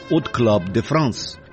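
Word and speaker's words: Hot Club de France: В 1932 году Юг основал «От Клаб де Франс», первое «H», французы не произносят. Отсюда и фонетическое «от Club de France».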